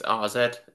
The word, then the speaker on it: AZ: I think it's RZ. It's weird. It's an A, but I think they pronounce it RZ Alkmaar. Of course.